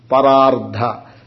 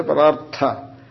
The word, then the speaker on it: Parardha: You should not say Parartha. There must be distinction between Ardha and Artha. Everything is pronounced the same way. Ardha, ardha, ardha. All is ardha. That doesn't work like that.